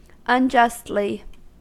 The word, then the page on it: unjustly: American English